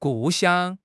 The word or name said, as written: 故乡